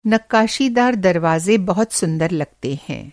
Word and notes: नक़्क़ाशीदार: نقشیدار

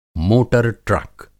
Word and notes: मोटर ट्रक: Hindi; موٹر ٹرک

